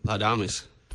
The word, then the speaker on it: Laudamus: Have I mispronounced the name of this ensemble? – No, you nailed it. Laudamus.